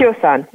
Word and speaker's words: Tiossan: the next one, the second one, is uh, Tosano. Right? Am I pronouncing that right? I think it was Tiossan.